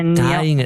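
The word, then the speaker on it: Thayngen: Habe ich das richtig ausgesprochen? – Taingen.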